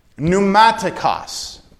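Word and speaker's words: Pneumatikos (πνευματικός): It's the Greek word Pneumatikos. P-N-E-U-M-A-T-I-K-O-S.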